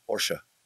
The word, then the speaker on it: Porsche: Is it Porsche or Porsche? – Porsche. Porsche is a two-syllable word.